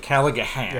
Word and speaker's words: Callaghan: So Andrew Callahan, which I kept spelling this in my first piece, it has the G, that particular silent G. – The Irish G-H-A-N, Callaghan. Yeah. Not like Dirty Harry Callahan, which was just the H-A-N.